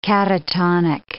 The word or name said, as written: catatonic